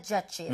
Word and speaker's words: judge: 그런데 이거 발음을 할 때요. D 사운드를 약하게 해서요. judge. 그리고 마지막에 입모양이 하고 모여야 됩니다.